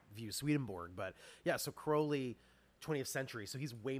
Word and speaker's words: Crowley: And then the other guy that I know almost nothing about, too, is it Crowley or Crowley? Aleister Crowley? – So it rhymes with holy, as people will Crowley. – Say. – Okay. – So it's Crowley, yeah.